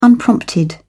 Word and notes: unprompted: British English

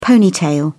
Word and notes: ponytail: British English

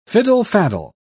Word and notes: fiddle-faddle: British English

